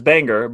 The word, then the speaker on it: Bangor: But I get a lot of grief for it, the way I pronounce Bangor, because it's pronounced Bangor. – Bangor? – Well, not over where we're at. It's Bangor. But over there, Bangor, Maine. Okay? That's how you say it. Bangor.